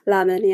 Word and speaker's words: Lamine: Well, let's, the name of the character, one is Mina, and the other one, if I pronounce it wrong, let me know. It's Lamine? – Lamine, yeah. – Lamine, okay. And then we have Lay, looks like L-A-Y-E.